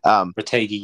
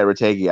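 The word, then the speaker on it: Retegui: You could argue, I don't know how much was paid for like Radagui or whatever his name is. I don't know how to say it, but Retegi, yeah. – Yeah, Retegi.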